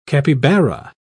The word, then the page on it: capibara: British English